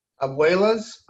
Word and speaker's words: abuelas: So but abuelas. Now that you say that, that's exactly how it's supposed to be said.